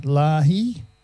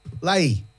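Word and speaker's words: Lahey: How would you pronounce that? Lahi? – Lay. – Alex Lay. – Lay. – Let's Go Out. – Just say it fast. Lay.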